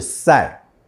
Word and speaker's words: 쌀: 那米的韩文呢是 쌀，发音像是沙后面加一个卷舌的儿，那你把 쌀 煮熟就会变成 팝，就是饭，发音呢就是趴下的趴，后面快速的把嘴巴闭紧。